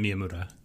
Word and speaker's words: Miyamura: Did I say Miyamoto? Because I meant Miyamura. I thought I said Miyamura, but my fault, Kate.